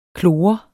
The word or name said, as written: klor